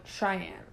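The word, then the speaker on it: Cheyanne: I'm like, Cheyenne. Like, it's right there. You know, it's like the capital of Wyoming.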